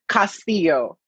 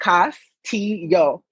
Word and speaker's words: Castillo: So say, it's the same with me. Castillo. Like, Castillo.